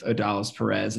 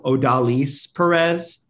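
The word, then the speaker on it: Odalis Pérez: And I remember leading up to it, someone had mentioned at some point that, like, you know, his name was pronounced, you know, Odalis Perez, or Odalis Perez, would be the better pronunciation. I'm obviously botching it now, but I was super hyped because everyone had called him Odalis Perez, 'cause that's kind of the Americanized version of what you would call him.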